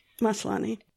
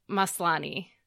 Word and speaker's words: Maslany: Maslany. – Maslany.